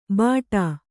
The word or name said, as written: ಬಾಟಾ